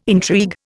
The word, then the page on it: intrigue: Indian